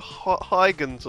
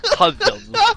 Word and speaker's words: Hudgens: How do you say Hudgens? – Hudgens. You say it like that, you say Hudgens.